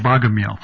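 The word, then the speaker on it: Bagemihl: Biological Exuberance by a man called Begumil, is that his name? – It's actually pronounced Begumil. Begumil.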